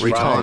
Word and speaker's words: Riton: I think it's Riton. – It's Riton.